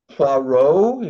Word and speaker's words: Poirot: And I'm all, actually, Poirot, if you say it correctly, that's a great series.